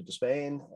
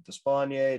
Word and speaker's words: Despaigne: Next is a guy who actually is the all-time leader in home runs in World Baseball Classic play, with seven, and that's Alfredo Despain, Despaigne, Despain, same thing. There's a through line in this that there's often all different ways to pronounce Cuban names.